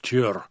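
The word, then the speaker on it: Tyr: muss man da etwas aufpassen mit der Aussprache. Das klingt fast etwas wie Tür, im Deutschen heißt auch Tür, ja, also dahingehend ist eben dieses Album, ja, das 1990 dann schließlich erschienen ist, schon ein Jahr auf das vorangegangene Album.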